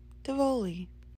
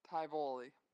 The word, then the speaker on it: Tivoli: I'm going to go with Tivoli. I repeat, Tivoli. – This pronunciation right here went for the strong I sound, Tivoli. I think it's probably the short one.